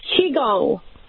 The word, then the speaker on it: Qigong: How would even we pronounce that? – It's Qigong. – Qigong. – So, Qigong.